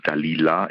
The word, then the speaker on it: Dalila: Und die Damen, das sind Léa Desandre als Timna und eben Dalila, im Französisch ausgesprochen Jacqueline Stucke, die machen das ganz, ganz exzellent.